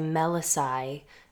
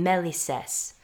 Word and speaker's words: Melissae: And you'll notice that throughout this episode, I say Melissae to talk about the bee nymphs in plural. And in ancient Greek, the word is Melissa for singular and Melissae for plural. But in modern Greek, they say Melissa for a singular bee and Melisses for plural bees.